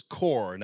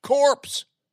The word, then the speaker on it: Corps: And actually, had Barack Obama read, a dictionary, and nowadays the dictionaries have pronouncers on them, you'd know it was core, not corpse.